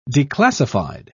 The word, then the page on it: declassified: British English